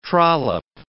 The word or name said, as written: trollop